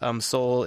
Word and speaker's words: Sol: Soul is spelled S-O-L.